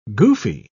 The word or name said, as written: goofy